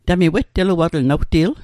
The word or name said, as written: wetteluatl